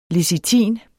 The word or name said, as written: lecitin